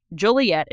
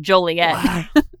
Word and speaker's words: Joliet: It's pronounced Joliet, and he says it's Joliet.